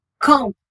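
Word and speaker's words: calm: For example காம் சிஏஎல்எம் இதனை காம் என உச்சரிக்க வேண்டும், எல் சைலண்ட்.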